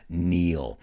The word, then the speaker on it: Neil: Bueno, no se escribe igual, pero el nombre Neil se pronuncia igual que estar de rodillas. La diferencia estar de rodillas tiene una K muda, Neil.